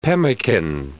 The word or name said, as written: pemmican